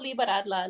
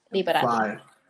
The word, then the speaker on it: liberarla: I speak Spanglish, so like I always switch back and forth, but it's spelled L-I-B-E-R-A-R-L-A. Liberadla.